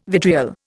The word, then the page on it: vitriol: Indian